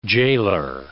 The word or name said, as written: jailer